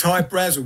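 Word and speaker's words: Hy-Brasil: But it's High Brazil because that's how the Irish would pronounce it, because it's an it's an island that's off the southwest coast of Ireland.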